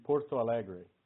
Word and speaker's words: Porto Alegre: How do you say, where did you go to school for your veterinary medicine degree? – So that would be Porto Alegre.